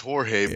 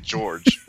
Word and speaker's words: Jorge: Yeah, well, I mean, in Spanish, it's Jorge, but I mean, there are those people out there who pronounce it George or Georgie.